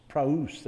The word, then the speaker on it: praos: I'm probably saying that wrong. That's what it is, what meek is in the Beatitudes. Maybe I should say praos. Praos. Praos, there you go.